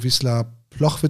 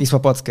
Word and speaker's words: Wisła Płock: Nochmal kurz zu der Leihe, dass du zu Wisla Ploch wird's ausgesprochen, glaube ich, ne? – Wisła Płock, ja. Das ist genauso wie bei Miłosz, dieser L mit so einem Strich, das ist L und Wisła Płock, aber kann man auch Ploch sagen.